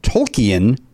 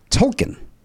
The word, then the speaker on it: Tolkien: The answer is J.R.R. Tolkien or Tolkien. – Those are pronunciations. – so he's going alternate pronunciation. – Some people pronounce it Tolkien. – Either answer is okay.